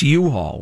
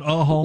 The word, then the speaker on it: U-Haul: Is it a long U or a short U? Is it U-Haul or a haul? – It's U-Haul. – It's not a haul.